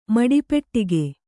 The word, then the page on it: ಮಡಿಪೆಟ್ಟಿಗೆ: Kannada